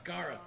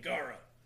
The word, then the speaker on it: Gaara: Actually, I put him at number two on my And how is that pronounced? Gaara? – Gaara. – Okay. Yeah. Double A. Gaara.